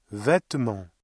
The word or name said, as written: vêtement